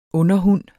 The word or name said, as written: underhund